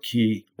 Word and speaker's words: Quay: We went to visit friends that lived in Robertson Quay or Quay, depending upon how you like to say it. I've heard it on tour buses. I've heard Clark Quay, and it's spelled Q-U-A-Y, and they call it Clark Quay.